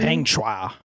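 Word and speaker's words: Peng Shuai: You texted me about this weird case that, like, this tennis star, Chinese tennis star, Peng Shui, anyone? – Peng Shua. Peng Shua.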